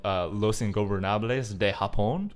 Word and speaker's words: Los Ingobernables de Japón: So he has a stable called Los Ingobernables de Japón. – What? – Because he wrestled. – I want to say, beautiful pronunciation of that.